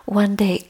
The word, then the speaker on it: Vande: From that verb vandana, which is to pay respects or pay homage, you say Buddhang Vande, Dhammang Vande, Sangham Vande, V-A-N-D-E, like accent aigu, accent on the E. So it sounds French, but it's Pali, there's no accent, but it's one day. And it sounds like English. Bhutang, one day. Buddha, one day.